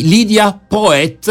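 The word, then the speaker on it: Lydia Poët: Parleremo, eh, beh, insomma, di una eh, di Lydia Poet, è così che si pronuncia, prima avvocata in Italia, ma insomma ne parliamo fra poco.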